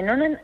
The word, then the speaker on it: Noonan: Síndrome de Noonan, Asturias.